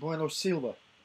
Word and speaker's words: Bueno Silva: Bueno Silva.